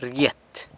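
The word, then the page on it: rgyet: San Lucas Quiaviní Zapotec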